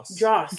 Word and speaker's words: Joss: How do I say it? Like his name, J-O-S-S. – Is it Joss? – Joss. Okay.